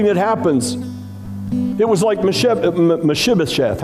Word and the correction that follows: Mephibosheth: How do you say that? Uh, Say it. Mephibosheth. Fibba, Mephibosheth.